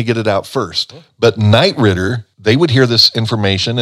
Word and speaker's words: Knight Ridder: That's a news source publication, and there are many Knight Ridder publications throughout the United States.